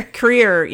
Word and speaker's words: Kreier: Creer. Yeah, K-R-E-I-E-R.